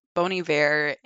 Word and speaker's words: Bon Iver: It was Bon Iver in Madison. – I love the fact that you pronounce Bon Iver right. That is that tells me that you actually know your shit.